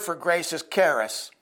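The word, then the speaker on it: charis (χάρις): When Jesus walked through Palestine talking about God's charis, C-H-A-R-I-S, but kind of pronounced with a K, more than that C-H is hard, his hearers knew what he meant.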